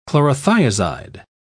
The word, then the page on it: chlorothiazide: British English